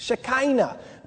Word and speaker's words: Shekinah: The Shekinah, or how do you say it? Shekinah.